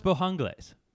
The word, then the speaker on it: Bojangles: Also, I found out that I have been saying it very northernly because I call it Bojangles, and it's supposed to be Bojangles. – I believe it's pronounced Bojangles. – And more are saying this as well. But I've been yelled at already. – You were putting the emphasis on the wrong part? – Yeah. On Jang.